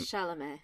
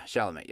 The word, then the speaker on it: Chalamet: We weren't sure how to pronounce his last name at the time. – Chalamet. – That's right, Timothy Chalamet. – Did I do it right? – Yeah, Chalamet, yep.